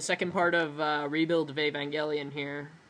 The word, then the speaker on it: Evangelion: Once I finish Eve Evangelion, I will. – Evangelion.